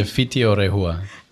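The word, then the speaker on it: Whiti o Rehua: Raúl Ortega es artista visual y profesor asociado en la Escuela de Arte Fiti o Terrehua. ¿Cómo se dice? – Se dice Fiti o Rehua. – Sí. Fiti o Rehua. – Es el nombre maori de la escuela.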